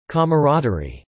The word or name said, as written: camaraderie